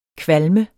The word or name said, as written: kvalm